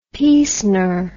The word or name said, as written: piecener